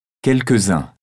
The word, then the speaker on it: quelques-uns: Il y a un trait d'union entre les deux éléments et on fait la liaison. – Quelques-uns. Quelques-unes. – On prononce le S final du pronom tous, T-O-U-S.